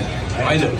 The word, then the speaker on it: Ajde: How you say it in Serbian? – Ajde. – Who? – Ajde Heat. Just Ajde. – Ajde Heat. Ajde Heat. Ajde Heat. Ajde Heat. – He said it's Ajde.